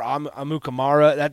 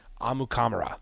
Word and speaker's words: Amukamara: I remember thinking, like, he just never told people, hey, that's not how you pronounce it. Amukamara or Amukamara, that's all wrong. Both of those are wrong. Amukamara. There it is.